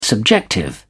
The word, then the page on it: subjective: British English